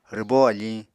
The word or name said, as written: rwbó afiñ